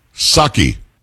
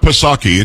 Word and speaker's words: Psaki: Psaki. – Psaki? – Psaki. It's not Psaki. It was just Psaki. You didn't pronounce the P.